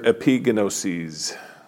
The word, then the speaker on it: epignosis: In the New Testament, the generic Greek word for knowledge is gnosis, G-N-O-S-I-S. But that's not the word Paul uses here in verse 2. He uses the Greek word epignosis. And yes, that's how it's pronounced because I looked it up.